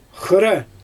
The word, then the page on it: xɯra: Xyzyl